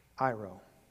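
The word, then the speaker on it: Iro (αἴρω): This word that is translated into English, takes away, the Greek word is spelled A-I-R-O, Iro.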